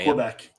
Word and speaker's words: Quebec: But this stuff, as reported by the Daily Wire, people who live in Quebec or Quebec, however it's pronounced, don't give a damn. – Quebec. It's Quebec.